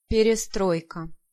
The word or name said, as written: Perestroika